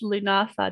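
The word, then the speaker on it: Lughnasadh: I learned to pronounce Lunasa, just as an aside, from the Dave the Bard song.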